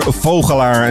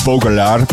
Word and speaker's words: Vogelaar: Peter Vogelaar, as they say in Dutch, but um, but his pronunciation will probably be Baudelaire.